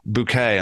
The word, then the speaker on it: Bucket: insists that her name is pronounced Bouquet.